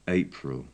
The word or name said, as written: april